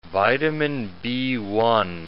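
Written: vitamin B1

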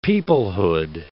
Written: peoplehood

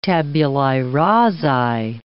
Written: tabulae rasae

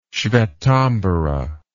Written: svetambara

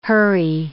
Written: hurry

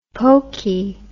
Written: pokie

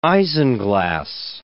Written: isinglass

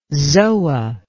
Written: zoa

